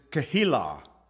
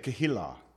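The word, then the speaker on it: Keilah: So if you look at chapter 23, verse 1 says, "Then they told David, saying, 'Behold, the Philistines fight against,'" now this is the Hebrew pronunciation, it's Kehilah. That's how they say it, Kehilah.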